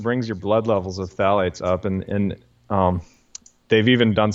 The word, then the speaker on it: phthalates: You know, because phthalates are also used. And by the way, phthalates are spelled P-H-T-H-A-L-A-T-E-S, but it's pronounced phthalate, like just T-H. – Okay. – The P-H is silent.